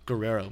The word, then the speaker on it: Guerrero: Guerrero.